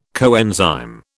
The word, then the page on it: coenzyme: British English